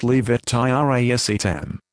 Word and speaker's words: levetiracetam: Active ingredient, levetiracetam, pronounced levetiracetam.